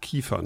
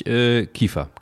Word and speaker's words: Kiefer: Und das ist im Grunde genommen eine Fortsetzung deiner kulinarischen Krimis rund um den Protagonisten Xavier Kiefer. Jetzt habe ich es doch französisch oder luxemburgisch ausgesprochen, aber es ist Kiefer, ne? – Es ist tatsächlich Kiefer, genau.